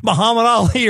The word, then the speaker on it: Muhammad Ali: He knows it's going to be Muhammad Ali or someone in that genre, if you will.